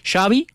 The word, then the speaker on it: Xavi: Entonces Xavi dice, Xavi como chocolate.